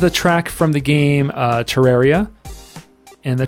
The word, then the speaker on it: Terraria: He's heard it pronounced Terraria and Terraria. But I think Terraria because of terrarium.